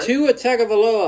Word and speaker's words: Tua Tagovailoa: Tua Tagovailoa, I believe I said that right.